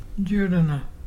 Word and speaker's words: jurruna: Djurdjana. Djurdjana is pelican. And to spell it is J-U-R-R-U-N-A.